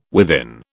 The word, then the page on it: within: British English